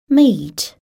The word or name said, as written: meat